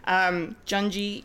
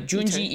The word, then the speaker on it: Junji: Junji Ito Maniac. – Junji. – Junji. J-U-N-J-I-I-T-O. – Junji. – Junji. Not Junji. There's my English pronunciation of a Japanese name.